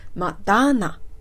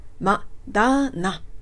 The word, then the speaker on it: Moderna: モデルナという 4 つの音ではなくて、マダーナ、マダーナという風に 3 つに分けて発音していきたいと思います。